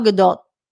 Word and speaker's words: Gadot: Katie in the Streamlabs says, according to GQ, an insider, Gal Gadot's name is pronounced to rhyme with dot, not dough. It just was slightly softer T than most Americans would use.